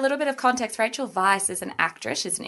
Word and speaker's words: Weisz: Rachel Weisz, and it's pronounced Weisz, people, we've Googled it, it's correct, has had a baby at 48.